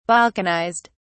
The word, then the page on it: balkanized: British English